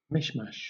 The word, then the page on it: mishmash: British English